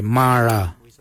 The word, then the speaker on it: Marraa: Number 9, Ma Kuju Kuju is spelled M-A-R-R, then it's G-O-O-D-J-A-L, and then again, G-O-O-D-J-A-L. Ma Kuju Kuju. For the number 10, we say Mara. Mara, which is spelled M-A-R-R-A-A. Mara for the number 10.